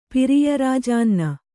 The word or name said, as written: ಪಿರಿಯರಾಜಾನ್ನ